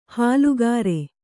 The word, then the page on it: ಹಾಲುಗಾರೆ: hālu gāre